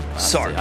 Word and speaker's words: Sartre: All right, I got to read some Sartre. – You don't pronounce R-E. – You don't. – Sartre. – I'll start there. – Sartre. – I got to read some Sartre. – Rhymes with Jamie Tart.